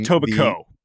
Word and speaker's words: Etobicoke: I believe the name of the town, dig into the mind, is Etobicoke. From Etobicoke, silent K.